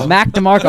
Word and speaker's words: Mac DeMarco: And then we are going to cover the new album by Mark DeMarco, right? – Mac DeMarco. – I said it so wrong.